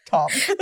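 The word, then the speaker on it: Ptom: P-T-O-M-P. Tom. – The P's are silent. – It's giving like, celebrity name. – Yeah, it only goes by Tom.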